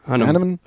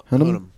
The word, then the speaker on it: Hunnam: How do you pronounce that guy's last name? Hunnam. – Hunnam. Charlie Hunnam.